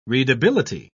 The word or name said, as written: readability